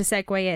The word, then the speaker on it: segue: No, I meant too cool for the weird linking things you're meant to do in media to make things flow, which is spelled Segu, but not pronounced that way, fortunately. But I thought I'd use this opportunity to demonstrate what a segue is, because I've got a really good one to link these two songs.